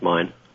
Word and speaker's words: Myine: And is it Mine? Is that the correct pronunciation? – Yep. It's spelled M-Y-I-N-E, but pronounced Mine.